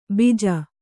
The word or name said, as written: ಬಿಜ